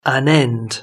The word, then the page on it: an end: British English